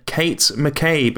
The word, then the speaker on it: Kate McCabe: And with us this time, we have a special guest in Kate McCabe.